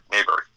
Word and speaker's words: Maberry: They want to spell it M-A-Y-B-E-R-Y. It's... you know, restrain yourself. Y is there so the English can figure out how to pronounce a Scottish last name. M-A-B-E-R-R-Y. Long A Lincoln Bakery, Mayberry. If you look up Jonathan Mayberry, you will find my Twitter, Facebook, Instagram, website, and everything else.